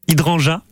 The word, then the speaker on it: hydrangea: Vous allez me dire, si je le prononce bien, hydrangea. Hydrangea. Hydrangea, oui, c'est ça.